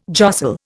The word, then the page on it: jostle: Indian